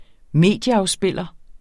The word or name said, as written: medieafspiller